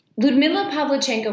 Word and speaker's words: Ludmila Pavlichenko: So, Ludmila Pavlichenko was born in 1916.